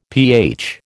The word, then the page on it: pH: American English